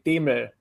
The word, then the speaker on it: Demel: Um, it's it's gotten butchered, but yeah, the original pronunciation is actually Demel, which is impossible to say in English.